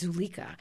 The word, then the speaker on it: Zuleika: And when I've lectured about it, and people will say, no, it's not Zuleika, it's Zuleika. And then I've had to go back to the preface where Beerbohm, in a late edition, explains how to say her name, and it's Zuleika.